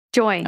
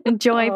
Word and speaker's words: Joi: Joy. – Okay. It's spelled J-O-I, and I had a moment of going, is that pronounced Joey or Joy? Because I couldn't remember. – Joy, played by Paula Jai Parker.